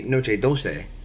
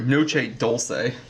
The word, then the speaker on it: Noche Dulce: I'm actually going to correct you. It is the Noche Dulce.